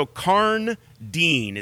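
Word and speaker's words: Karndean: It's so Karn Dean is how you pronounce that.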